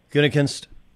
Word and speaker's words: Gutekunst: He doesn't care for, is it Guten? How do you pronounce the last name? – Gutekunst.